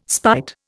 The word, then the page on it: spite: Indian